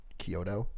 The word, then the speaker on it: Chiodo: His name, I assume it's spelled C-H-I-O, Chiodo, Chiodo. – C-H-I-O-D-O.